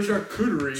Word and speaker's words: charcuterie: It'll be a chartreuse board. It's a chartreuse or a charcuterie.